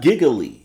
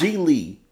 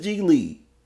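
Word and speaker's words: Gigli: I think it's G-I-G-L-I. Giggly? – It's not Giggly. – Or Giggly? – It's not Giggly. It's G-Lee. I know it is G-Lee.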